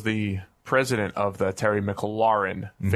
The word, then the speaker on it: McLaurin: It's a cool sounding nickname, except I'm led to believe they're saying that because there's an F1 team called McLaren, which is completely different sounding to McLaurin. Like it's a whole different vowel sound.